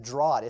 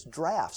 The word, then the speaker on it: draught: I think my whole life I said draught. It's draft.